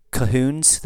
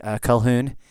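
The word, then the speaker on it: Colquhoun: Anyway, over in America, a lot of people just drop out that Q that's in it, and they just, you have Calhoun and Cahoon, and anyway, so the MacAulays border them.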